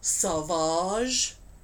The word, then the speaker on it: Sauvage: I believe it would have been pronounced Sauvage, because it was Savage, but with a U in it.